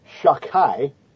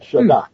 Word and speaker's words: Shaddai: This divine name, which should be pronounced Shakai, okay, not Shaddai, but Shakai. I don't know, I don't see a K there anywhere,